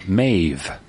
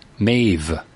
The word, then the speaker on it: Meadhbh: Here's how her first name was spelt. Is spelt M-E-A-D-H-B-H. – H-B-H. – Yeah. – Okay. – You know how that's pronounced? – Absolutely not. – Well, it says right here, Maeve. – Maze. – Maeve. – Maeve.